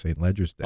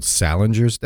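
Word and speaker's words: St. Leger's Day: They said that's how it's pronounced, St. Ledger's Day. – Oh, wow. Okay. – Now, one of them said someone snooty would say it's called Salinger's Day.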